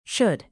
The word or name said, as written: should